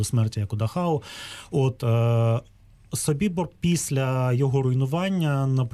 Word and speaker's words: Собібор: Ну, власне, я певно з цим погоджуся, але у випадку Собібора, я наголошую, що я вживаю такий наголос через те, що цей табір розташовувався на території Польщі, і поряд із ним знаходиться польське село Собібор. яке за правилами польської мови має наголос саме на голосний «і».